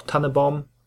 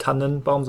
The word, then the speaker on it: Tannenbaum: Oder Gedenken sitzt man wirklich noch rund um den Tannebaum, ja, so spricht man es auch, Tannebaum, nicht Tannenbaum, sondern Tannebaum, ja.